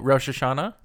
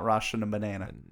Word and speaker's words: Rosh Hashanah: Oh, Rosh Hashanah? – Yeah, thank you. There we go. – Yeah. – Not Russian banana.